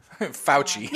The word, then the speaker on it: Fauci: You can see how do you say his name? – Fauci. – Yeah, that motherfucker.